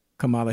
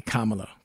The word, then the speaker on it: Kamala: Joe Biden is the president, and Kamala Harris is the. Or I would say Kamala. Kamala Harris. That's it. Kamala Harris. Say it right.